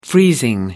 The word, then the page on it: freezing: American English